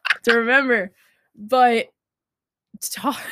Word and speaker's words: Toph: Toph.